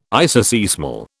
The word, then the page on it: isoseismal: British English